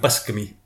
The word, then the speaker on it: Buscemi: I think you find it's pronounced Buscemi.